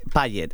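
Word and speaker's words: Pallett: es Owen Pollitt. Ajá. Owen Pollitt, que se escribe Owen Pallet, ¿vale? Con doble L, doble T. – Pallet. – Que es. Pallet, eso, Pallet.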